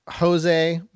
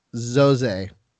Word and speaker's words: José: Looks like it's Jose, but it's actually José.